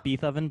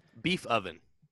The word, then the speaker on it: Beethoven: So it's pronounced Beethoven, but continue. – It's Beethoven? – Beethoven. Beef. – Oh, Beethoven. – Yeah, just, I mean, how it's written. – Beef oven. – Beef oven, yeah.